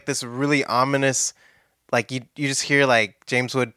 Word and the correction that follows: James Woods: talking. James Woods. Gosh, I can't say his name. James Woods talking over the wedding.